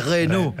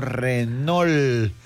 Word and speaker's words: Renault: Va, imagínate, yo decía Renault. Va, eso es Renault. Aquí decimos Renault.